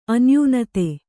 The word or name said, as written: ಅನ್ಯೂನತೆ